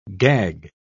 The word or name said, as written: gag